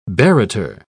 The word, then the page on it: barrator: British English